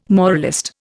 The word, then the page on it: moralist: Indian